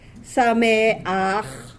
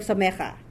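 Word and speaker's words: Sameach (שמח): We read the vowel first. Sameach. Okay, instead of Samecha.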